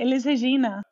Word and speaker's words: Elis Regina: Elis, that sounds great. Elis Regina.